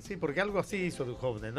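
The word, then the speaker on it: Dujovne: Dujon. ¿Lo dije bien? – Ah, de Duhovne.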